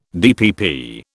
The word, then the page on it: DPP: British English